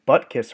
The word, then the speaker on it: Butkus: Is the Butt Kiss Award? Am I saying that right, Pat? Butt yes, Kiss. you are.